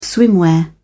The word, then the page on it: swimwear: British English